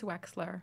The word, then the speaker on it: Wechsler: Is it Wechsler or Wexler? – I think it's Wexler. – Wexler, but not spelled like Pat Wexler.